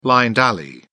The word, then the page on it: blind alley: British English